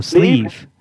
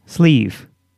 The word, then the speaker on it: Sleeve: Your name is Steve? – No, Sleeve. Sleeve. – Spell that, please. – T-H-A-T.